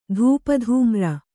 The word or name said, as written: ಧೂಪಧೂಮ್ರ